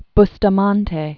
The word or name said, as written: William Alexander Bustamante